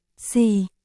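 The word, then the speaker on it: si (四): Na ná, nhau, trong tiếng Trung, số 4 đọc là, si,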